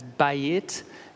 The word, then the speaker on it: bayit: The Hebrew word, which in English we would pronounce as bayit, which means house, has two meanings.